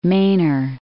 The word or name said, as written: Mainer